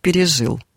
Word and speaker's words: пережил: Мы сегодня практически не слышим в средствах массовой информации ударение, такое ударение у глагола «пережить» в прошедшем времени как «пережил». Мы все слышим, что говорят «пережил, пережил», да? Он пережил эти события тяжело. Вот как раз Сергей Михайлович Волконский, он категорически возражал против такого ударения, потому что оно было ненормативным для того периода.